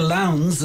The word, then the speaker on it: Lowndes: Neil Lowndes, is how I should pronounce it now. – Yeah, right. Okay, like Sarah on the cover of the Bob Dylan album, and Marion Campbell as well.